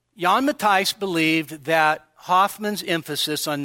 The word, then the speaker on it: Jan Matthijs: And you can see up there, Jan Matthijs or John Matthias, all right? That would be the anglicized pronunciation.